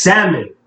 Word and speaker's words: salmon: But she's saying a silent letter that's not supposed to be said. That's not supposed to be pronounced in the word. – How do you know? – It's salmon, right? – But it's spelled S-A-L-M-O-N.